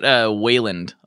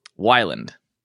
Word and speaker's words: Wyland: I get Wayland a lot. I'm like, Blake Wayland. Like, there's no A there, but hey, you know? – Oh, wait. How do you say it? – Wyland.